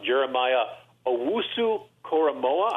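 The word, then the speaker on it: Jeremiah Owusu-Koramoah: Jeremiah Owusu-Koramoa. I hope I'm pronouncing that correctly. – You do. I just say J-O-K.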